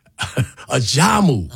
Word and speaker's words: Ajamu: Put the accent on the second syllable. – Yes. – Talk. Ajamu.